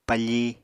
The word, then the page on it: pafi: Apatani